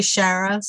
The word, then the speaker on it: Shara: It goes either way. You know, I have never been attached to, that's just personal pronunciation. As long as you don't call me Sharon or Sarah, you know, which is not my name. – But you got it. – My parents say Shara, so technically since they made the name up, but Shara is fine.